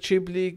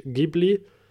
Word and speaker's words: Ghibli: Sag ich doch ähm, ja, wieder, ich weiß nicht, wie man ausspricht, Ghibli, Ghibli. Ja, hey, es, es scheidet sich geistig. Ich glaube, die offizielle Aussprache ist Ghibli, aber äh, ja, es, ist, es tut niemand blöd, wenn du Ghibli sagst.